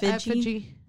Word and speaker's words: effigy: Eff- no, it's effigy. Effigy. It's effigy. Okay, mm-hmm.